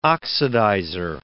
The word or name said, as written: oxidizer